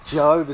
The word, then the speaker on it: Job: The Bible story of Job, Job is how it's pronounced.